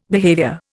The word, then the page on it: behaviour: Indian